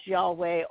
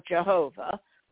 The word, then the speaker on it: Yahweh: There's Yahweh, Jehovah, same name, the Yod-Heh-Voh-Heh of the Jewish alphabet, the Hebrew alphabet is the Yod-Heh-Voh-Heh, and he's known either as, that's pronounced either as Yahweh or Jehovah.